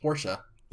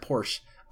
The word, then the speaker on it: Porsche: Fun fact, I've learned today that it's pronounced Porsche. – Yeah. – Didn't know that. I always said Porsche.